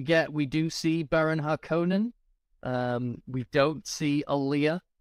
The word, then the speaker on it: Harkonnen: I listened to recordings of of Herbert, and he said Harkonnen, and ah.